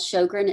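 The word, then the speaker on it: Sjögren: I have a disease called Sjogren. It's spelled S-J-O-G-R-E-N-S, Sjogren syndrome.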